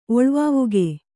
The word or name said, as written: ಒಳ್ವಾವುಗೆ